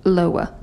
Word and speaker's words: Loa: Loa.